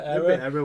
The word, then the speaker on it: Arra: I never know how to pronounce these guys, but Era, A-R-R-A, Era. – Era, wasn't it? – They've got some bangers.